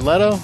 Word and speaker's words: Leto: And then Christian Bale in that movie and Jared Leto in that scene, too. One of our favorite bands. – Is it Leto? Is that how you pronounce it? – Yeah, Leto, yeah.